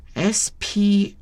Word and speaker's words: sprach: 那么它的原文是德语哈，我不敢乱发音，第一个字是 ALSO 啊， ALSO 阿索嘛，然后第二个字是 SPRACH SPARACH，那个 CH 在德语发音应该是喝。